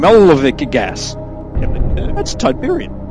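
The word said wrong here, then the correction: Tibanna: Tibanian. Tibana gas.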